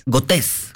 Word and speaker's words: Gotés: Gotés. ¿Como Bigotes? – ¿Cortés? – sin el bi. – Pero bigotes, sí. Con bigotes, acento en la E.